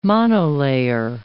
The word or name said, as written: monolayer